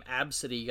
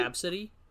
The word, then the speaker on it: Abcde: It's like the name Absidy got hella popular for a while. – Fucking what? – Absidy? It's spelled A-B-C-D-E.